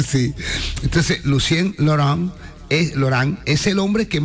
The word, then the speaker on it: Lucien Laurent: El señor Lucien Laurent. – ¡Mierda! Good pronunciation. – Digo a los oyentes porque Sandy fue la que me dijo cómo tenía que decirlo. – Lucien Laurent.